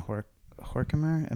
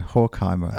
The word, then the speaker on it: Horkheimer: So I'm thinking also, so there's that recently published conversation between Adorno and Horkheimer. Am I saying his name right? – Yes.